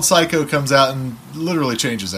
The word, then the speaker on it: Psycho: Then there's a little bit of a turning point in horror cinema where a film by the name of, is it Pfizco? Psycho, that's how you pronounce it.